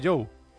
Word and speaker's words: Zhou: Es el chino Zhou, bueno, sí, aunque en realidad hablé con él y hay que llamarle Joe. – O Zhou. – O sea, él se escribe Zhou, o sea, Zhou, sí. Pero él quiere que le... O sea, bien pronunciado en chino es Joe.